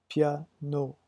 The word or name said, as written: piano